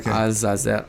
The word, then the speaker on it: Azazel: Azazel, that's how you say it? – That's how I always said Azazel. I think in Hebrew, probably Azazel.